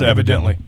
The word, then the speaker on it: evidently: Is it evidentially or no, is evidently. It evidently? – It's evidently. – Is it evidently? Oh, okay. – Evidently. Evidentially, you'll say it correctly.